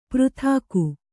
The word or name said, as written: ಪೃಥಾಕು